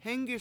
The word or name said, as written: hEnggis